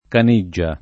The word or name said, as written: Caniggia